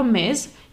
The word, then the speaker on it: Ms: MS vyslovíme jako MIS, jako opravdu kdyby tam bylo Z na konci, a Miss jako slečna vyslovíme jako Miss se s na konci. Takže Miss, nebo je zvučný z na konci a Miss nezvučné S.